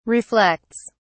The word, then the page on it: reflects: British English